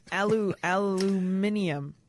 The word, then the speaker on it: aluminium: That's the British. That's how the British say. Aluminium. – Which makes sense because they use all the letters. – Right. Well, they like to add U's to their words anyway. – Right. – Flavor, favorite. – Yeah, but in this one, at least they're using all the letters. – Right. – Every single letter is pronounced.